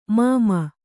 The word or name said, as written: ಮೂಮೆ